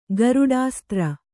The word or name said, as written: ಗರುಡಾಸ್ತ್ರ